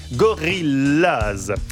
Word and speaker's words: Gorillaz: Gorillaz se prononce comme en anglais Gorillaz.